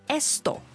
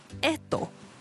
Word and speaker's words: esto: 嗱，我俾個例子大家啦，如果咧我想指呢、這個呢樣，咁西班牙文正確或者係清楚嘅讀音咧會係 esto。系啦，咁但系咧，古巴人咧就会省略咗个 S 音咧，读成 edo。